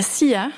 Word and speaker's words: La Silla: Et vous êtes même parti en 2012 au Chili pour 19 nuits d'observation sur le télescope suisse à la SILLA. – À la SIA.